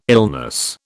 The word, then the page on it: illness: British English